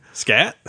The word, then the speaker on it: Skat: It's spelled S-K-A-T. – Scat? – Yeah, Scott.